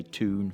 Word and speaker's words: town: quite rare now in that part of Yorkshire, but you still hear it in places like Newcastle upon Tyne. They say toon for town.